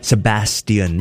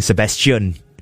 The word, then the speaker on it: Sebastian: Sebastian. That's actually how you say it, you know? But in Singapore, we grew up saying Sebastian.